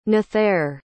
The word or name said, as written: nathair